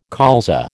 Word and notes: colza: American English

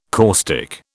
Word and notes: caustic: British English

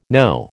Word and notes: Noh: American English

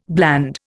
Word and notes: bland: Indian

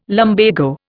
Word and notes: lumbago: Indian

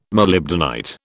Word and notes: molybdenite: British English

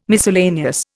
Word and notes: miscellaneous: Indian

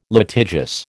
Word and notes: litigious: American English

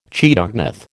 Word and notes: chaetognath: American English

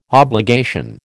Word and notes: obligation: American English

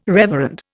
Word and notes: reverend: Indian

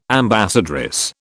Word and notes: ambassadress: British English

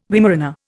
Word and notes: Weimaraner: Indian

